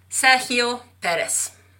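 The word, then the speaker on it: Sergio Pérez: Sergio Perez.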